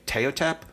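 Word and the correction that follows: Ptahhotep: the teachings of Teotep? – Ptahotep. – Ptahotep. Okay. I was doing the silent P.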